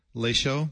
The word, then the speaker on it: Le Show: But thank you for letting me on Les Show again after a decade. – How did you pronounce it? – Lay Show.